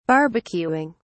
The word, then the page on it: barbecuing: British English